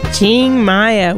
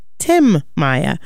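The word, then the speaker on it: Tim Maia: Ching, it sounds like, well, I'm pronouncing it the Brazilian way, but if you had to say it by reading it in English, you'd say Tim Maya.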